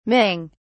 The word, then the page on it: ming: British English